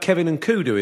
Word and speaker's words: Kevin N'Koudou: And we'll have Kevin and Kudu in by then, all being well. – If you're the first person that... that's how you say his name, isn't it? – Yeah, it's all done.